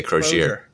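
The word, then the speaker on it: Croshere: How do you say it? I say Crozier, which is the right way.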